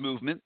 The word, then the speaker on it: MVMT: It's MVMT. That's how it's spelled. It's pronounced movement, but it's MVMT.